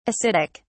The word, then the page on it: ascitic: British English